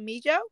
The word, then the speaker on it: Mijo: We have the owner and creator of House of Mijo. Am I pronouncing that right? – You are.